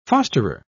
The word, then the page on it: fosterer: British English